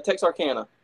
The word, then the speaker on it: Texarkana: A show on Monday night in Texarkana. Am I saying that right? In Texarkana? – Texarkana. – Texarkana, Arkansas.